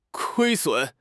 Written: 亏损

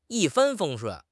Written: 一帆风顺

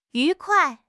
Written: yu2 kuai4

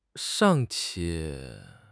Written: 尚且

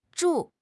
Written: zhu4

